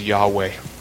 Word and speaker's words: Yahweh: it is to render the Hebrew tetragrammaton that is best pronounced as Yahweh.